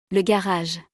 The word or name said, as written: Le garage